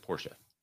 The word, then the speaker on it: Porsche: No, the man's name was Porsche. He pronounced it Porsche.